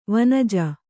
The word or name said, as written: ವನಜ